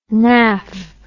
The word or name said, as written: naff